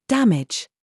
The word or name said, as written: JUDGE